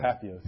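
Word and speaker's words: Patheos: And there's plenty of people like this on the, is it Pathos? How do you say Pathos? – Pathos blog.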